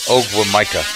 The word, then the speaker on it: Ogwumike: Ogwumike? – Yeah. – NECA? – Yeah. Okay. Going NECA, okay. – I just, let's stick with my pronunciation. Ogwumike.